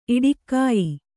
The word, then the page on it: ಈಡುಗಾಯಿ: kannada